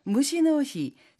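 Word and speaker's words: 무시노히 (虫の日): 일본에는 숫자 6, 일본어로는 무라고 나타내고요. 숫자 4, 시라고 하는데요. 이 숫자 6과 4의 발음을 딴 무시노히, 즉 벌레의 날이라는 것이 있어요.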